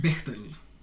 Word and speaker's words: Bechdel: Bechdel. vad heter det? Varför kan jag inte uttala det? – Bechdel. – Bechdel, ja. Mm.